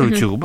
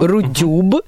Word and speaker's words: Рутюб: На Рутюб. – Рутюб.